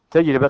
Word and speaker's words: تجربة: أو قال هنا تعريف آخر، أو هو التعبير عن تجربة، تجربة تنطق هكذا بكسر الراء، بكسر الراء، لأنها من الفعل جرب، مضارعه يجرب بكسر الراء، ومصدره تجربة، وجمعه تجارب، جمعه تجارب.